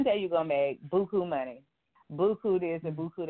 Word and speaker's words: Beaucoup: Now, real briefly, tell me about your nonprofit organization, Beaucoup. Now, do you say it like that? You say it in the French way?